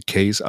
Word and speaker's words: Cayce: Also sie wird eigentlich C-A-Y-C-E geschrieben, aber sie selbst spricht sich aus wie Case, also C-A-S-E.